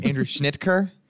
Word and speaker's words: Schnitker: We did this a bunch before, but it's Andrew Schnitker.